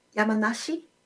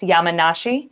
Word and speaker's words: Yamanashi: I am in the Yamakanshi. – Yamanashi. – Apologies, the interpreter mispronounced. Yamanashi province near Tokyo.